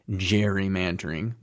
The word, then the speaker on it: gerrymandering: which eventually turned into that soft G, gerrymandering,